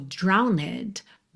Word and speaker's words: drowned: O verbo drown no passado é drowned. Tem um E aí depois desse N, tá? Se escreve como drowned, mas você não pronuncia essa letra E.